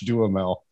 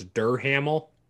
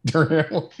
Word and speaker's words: Duhamel: and playing Spaceman Lee is Josh Duhamel. – Well, there you go. There you go. – Hey, so, no, you pronounce that wrong. It's Duhamel. – Duhamel. Yeah.